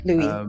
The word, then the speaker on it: Lewis: Louis. – Louis. So it's spelt Louis, but it's pronounced Louis. – No, it's spelt Louis. – Oh man, all this time. – Louis. Louis is L-E-W-I-S. – Oh, so I've got L-O-U-I-S.